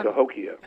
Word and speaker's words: Cahokia: And I, please correct me if I'm mispronouncing this. The Cahokia Mounds in Illinois. – Um, Cahokia. – Ho- Cahokia okay.